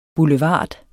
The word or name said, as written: boulevard